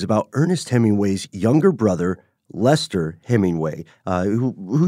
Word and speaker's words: Leicester: But it was spelled like the name of the town in England, Leicester, which looks like it should be pronounced Leicester. That's L-E-I-C-E-S-T-E-R.